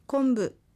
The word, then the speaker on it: コンプ: 今回コンプという単語が出てきましたが、日本語でコンブと発音しますし、アイヌ語でもやはりそう発音します。 N という音に続く子音は濁音になりやすいのです。